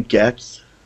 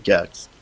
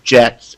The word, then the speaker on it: Gex: Gex, and the salesperson behind the counter was like, "Oh no, actually it's pronounced Jex, and it's about a dinosaur."